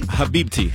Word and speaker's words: Habibti: Is it Habibti? – Habibti.